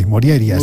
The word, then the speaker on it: Morjaria: Bueno, la J normalmente se pronuncia como I. Moriaria sería.